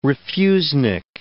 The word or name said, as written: refusenik